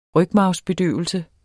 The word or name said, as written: rygmarvsbedøvelse